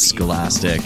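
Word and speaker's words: Skulastic: Her name is Scholastic. It is spelled in a very unscholastic way. That's S-K-U-L-A-S-T-I-C.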